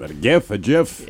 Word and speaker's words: GIF: According to Steve Willite himself, the best way to pronounce the word is with a soft G. – So GIF.